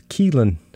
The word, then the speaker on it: Keelan: She has this very long, difficult Irish name, but the pronunciation is Keelan.